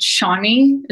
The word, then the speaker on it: Chani: Another app I really like a lot more is called Shani. It's by this astrologer. It's spelled C-H-A-N-I.